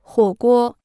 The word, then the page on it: 火锅: huǒ guō